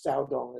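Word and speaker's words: Caodong: In Chinese, it's Saodong is pronounced.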